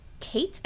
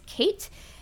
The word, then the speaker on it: Keitt: But Representative Lawrence Kate, I think is how you pronounce his name, K-E-I-T-T, in South Carolina said duels were for gentlemen and Sumner was not a gentleman.